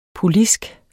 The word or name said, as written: polisk